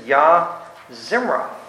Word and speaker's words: Yah Zimrah: And tonight we're going to look at a name that's Yah Zimrah. That's how it's pronounced.